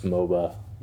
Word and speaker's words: MOBA: it's pronounced MOBA, by the way.